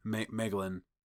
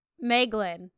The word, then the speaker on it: Meglin: I like the theory that this guy is Meglar. Is that how you say it? – Meglin. – Meglin. – Yeah.